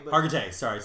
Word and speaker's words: Hargitay: Is it Mariska Hargitay? Is that how you say her name? – Hargitay.